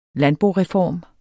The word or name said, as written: landboreform